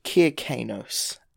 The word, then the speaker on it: Kir Kanos: That's how I've always heard his name pronounced, so that's what I've always gone with, but it's spelt K-I-R-K-A-N-O-S, so Kier, or like Kerr, but, you know, Kier Kanos sounds a bit better than Kerr Kanos, so we'll stick with Kier,